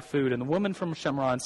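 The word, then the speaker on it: Shomron: And it says this, John chapter 4, verse 5 says, he came to a town in Shamron, which is the Jewish pronunciation of Samaria, called Shechem, near a field of Yaakov, Jewish pronunciation of Jacob.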